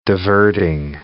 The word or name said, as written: diverting